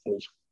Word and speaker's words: Nietzsche: I'm probably butchering the pronunciation. Whatever German's listening I was probably like, oh, it's Nietzsche.